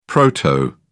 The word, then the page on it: proto: British English